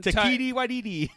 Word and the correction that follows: Taika Waititi: Taika Waititi. – Yeah, Taika Waititi.